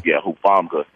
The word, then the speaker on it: Hufanga: Hufanga.